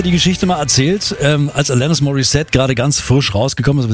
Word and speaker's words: Alanis Morissette: Bis dann irgendwann hieß, ja, Alanis Morissette, die kommt aus Kanada, englische Aussprache und so.